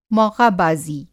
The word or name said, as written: मौक़ाबाज़ी